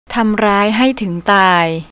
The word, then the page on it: tamraihai: thai